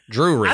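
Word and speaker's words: Drewry: Now, the patriarch, John Bell, and his wife, Lucy, moved their large family that included their children, John Jr., Drury, which is an interesting name, Drew R.Y., Drury.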